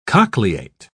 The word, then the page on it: cochleate: British English